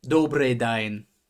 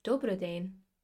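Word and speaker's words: добрий день: In Ukrainian is dobry день. Dobry день. Make sure you roll your R there.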